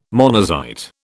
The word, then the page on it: monazite: British English